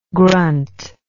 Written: granth

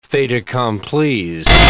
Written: faits accomplis

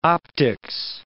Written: optics

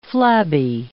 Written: flabby

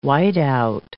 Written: whiteout

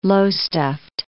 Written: Lowestoft

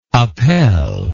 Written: appel

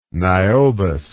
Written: niobous

